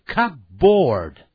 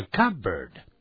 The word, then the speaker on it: cupboard: 这个时候可不能再念成 cupboard, 这个时候发音整个变了，叫 cupboard。